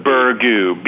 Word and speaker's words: Burgoo: Yeah, we're saying Burgoo, B as in boy, U R. – Yeah. – G as in girl, O-O.